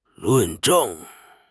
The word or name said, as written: 论证